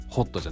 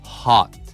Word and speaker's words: hot: これね、発音は一緒です、口を大きく開けて hot、ね、ホットじゃないね、 hot、ね、いう感じです。